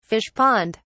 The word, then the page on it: fish pond: British English